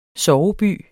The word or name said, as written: SOVEBYERNES